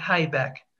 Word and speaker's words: Haibeck: H-A-I, B as in boy, E-C-K, Habeck. – Okay, so don't make the mistake that I've made and call her Susan Habeck, because that's not the correct pronunciation of her name. H-A-I-B-E-C-K. – C-K. Correct.